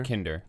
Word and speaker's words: Kinder: The Kinder? – Yeah, Kinder.